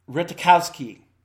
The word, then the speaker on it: Ratajkowski: There's a J. I'm not sure if that J is pronounced or not. Let me see. Emily Ratajkowski. How do you pronounce that last name? Ratajkowski. Okay.